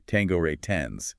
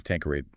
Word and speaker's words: Tanqueray: Right, which he explicitly mispronounces in that signature Southern drawl of his as Tanqueray 10s instead of Tanqueray, no.